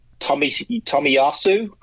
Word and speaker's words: Tomiyasu: And I may be butchering his name if I do. It's Tomiyasu, I believe it is. – That is correct.